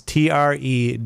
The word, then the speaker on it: Treworgy: It's Treworgy. – I can't pronounce it. – I want to say like tree orgy, but it's true orgy.